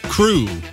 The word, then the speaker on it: Krewe: One from Lettuce called Crew. They spell it K-R-E-W-E, like a Mardi Gras crew.